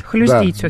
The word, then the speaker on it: хлюздить: Хлюздить, да, ударение хлюздить.